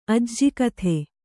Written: ಅಜ್ಜಗಿತ್ತಿ

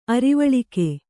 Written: ಅರಿವಳಿಕೆ

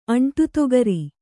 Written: ಅಂಟುತೊಗರಿ

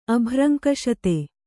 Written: ಅಭ್ರಂಕಷತೆ

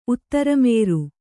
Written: ಉತ್ತರಮೇರು

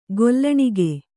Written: ಗೊಲ್ಲಣಿಗೆ

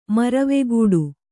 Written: ಮರವೆಗೂಡು

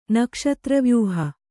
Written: ನಕ್ಷತ್ರವ್ಯೂಹ

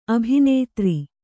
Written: ಅಭಿನೇತ್ರಿ

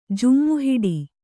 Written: ಜೋಮುಹಿಡಿ

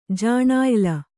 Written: ಜಾಣಾಯಿಲ

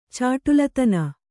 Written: ಛಢಾಳತನ

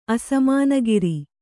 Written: ಅಸಮಾನಗಿರಿ

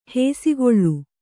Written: ಹಸೆಗಲ್ಲು

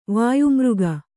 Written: ವಾಯುಮಾರ್ಗ

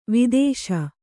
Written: ವಧಿಸು

